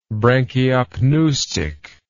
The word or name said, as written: branchiopneustic